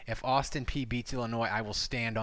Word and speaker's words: Austin Peay: Explain to me how the letters P-E-A-Y pronounce P? – I don't know. – I thought it was P-A. I thought it was Austin P-A.